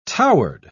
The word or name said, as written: towered